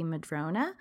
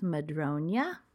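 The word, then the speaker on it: Madroña: So, all right, the second project that I'm working on is called the Madronia shawl, and it's a little odd because I would usually imagine that this would be Madrona shawl, but there is the little curly Q over the N, which is what I believe makes it pronounced Madronia.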